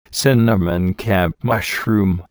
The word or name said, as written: cinnamon cap mushroom